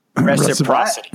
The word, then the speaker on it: reciprocity: No, but I think there should be some reciprocity, whatever that's called. – How do you say that word? – Otherwise, it's potentially creepy. – Reciprocity. – Mr. American. Reciprocity. There you go.